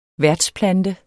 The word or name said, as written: værtsplante